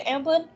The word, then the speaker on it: Amblin: So, uh, but yeah, they specifically was going for like an Amblin. Am I saying that right? Amblin.